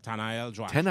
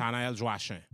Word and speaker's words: Tanael Joachim: Tanael Joachim. – Tanael? How do you say the last one? – Tanael Joachim. – Joachim? – Yeah, it's a French name.